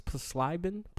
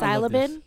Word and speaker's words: psilocybin: It's PSILOCYBIN. I think it's Psilocybin. Psilocybin. – Nah, I don't think you say the silent. P, so Psi, what is it? S-I-L? – S-I-L-O-C-Y-B-I-N. – Sobin? Sobin? – Psilocybin? – Psilocybin?